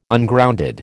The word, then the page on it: Ungrounded: American English